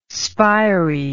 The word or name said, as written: spiry